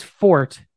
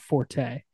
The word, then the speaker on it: forte: The catch and run by Jennings was his forte at, which is the correct pronunciation of that word, by the way, not forte.